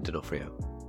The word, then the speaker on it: D'Onofrio: Yeah, Vincent D'Onofrio.